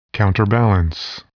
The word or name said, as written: counterbalance